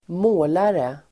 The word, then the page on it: målare: [²m'å:lare]